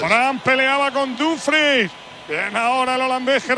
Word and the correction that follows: Dumfries: Lo digo bien, Miguelito, Dumfries. – Tengo dudas en esa posición. ¿Cómo es exactamente? – Yo creo que es Dumfries.